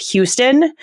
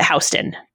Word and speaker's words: Houston: And she gathered that that was the street that has the same name as the city in Texas, but isn't pronounced Houston. Mm-hmm. So she tried, what is Houston? We pronounce it Houston for the Houston. For the New York City street. It's Houston Street.